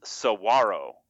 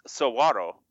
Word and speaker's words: saguaro: Also, a cactus symbol is pronounced saguaro. Kinda. Like, sort of. But, like, if you actually look at the, like, there's, like, this weird silent G thing where you need to do something a little bit different with the W.